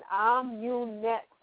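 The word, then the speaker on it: Amunet: Immunet. That's like saying immunet.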